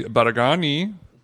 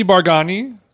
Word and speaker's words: Baraghani: we went to dinner with Andy Baragani. Are you laughing at my pronunciation of his last name? – I mean, that's the correct pronunciation. – Andy Baragani.